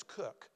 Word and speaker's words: Cook: My last name is Cook, and I have learned the spelling can be C-O-O-K, C-O-O-K-E, and it can also be spelled in the far northeast, K-O-C-H, and they pronounce it Cook.